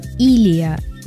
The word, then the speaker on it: Илия: А, Мариана, извините, Кононова пишет, что Илия. Ударение на первую гласную.